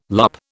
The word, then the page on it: loupe: British English